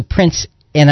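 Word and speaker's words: Prince Ea: Let's go to Prince. And I'm told that the pronunciation is Prince A. It's E-A, but it's pronounced A.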